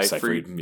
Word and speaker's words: Seyfried: Seyfried.